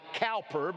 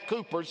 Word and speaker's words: Cowper: You spell his life C-O-W, like Cowper, but it's pronounced Cooper, C-O-O.